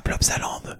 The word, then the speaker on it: Plopsaland: Pardon, comme un pot de Sealand. – Ah, Plopsaland. Ouais, Plopsaland, j'avais du mal à prononcer, ce que je l'ai jamais dit avant.